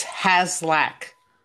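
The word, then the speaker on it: Haslach: It's H-A-S-L-A-C-H, and it's Haslack.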